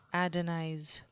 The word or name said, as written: adonize